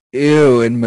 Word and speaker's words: Ewan: I was like, is there somebody else I don't know about named Ian McGregor? – No, sorry. Okay. I always try to say Ewan McGregor, but it sometimes just comes out as Ian McGregor. – Ewan McGregor.